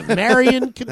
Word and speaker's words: Marion Cotillard: Marion Cotillard. – That's how her name's pronounced, goddammit. This is America.